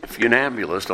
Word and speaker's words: funambulist: Who knows what see if I can pronounce this correctly, funambulist is. A funambulist. That's spelled F-U-N-A-M-B-U-L-I-S-T.